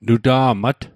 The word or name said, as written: nuta'mat